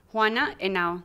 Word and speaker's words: Henao: Juana Henao. The H is silent.